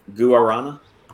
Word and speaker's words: guarana: Yeah, I do it like a G-E-R, like guarana. – Guarana. – No, I just don't pronounce the U.